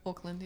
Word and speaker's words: Auckland: He's from Auckland. Is that how you pronounce it? – Auckland, yeah.